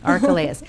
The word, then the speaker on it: Archelaus: It would have been Herod Antipas, Herod Philip, and Archelaus, as I'm going to say his name. – Yeah. – Does yeah. – that work? – Yeah. Okay. Works good. – Archelaus.